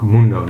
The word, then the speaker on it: Mundo: En je mag me ook Mundo noemen. Is, is Mundo beter? Dat, nou, dat is, dat is hoe mijn naam uitgesproken wordt.